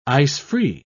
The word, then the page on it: ice-free: British English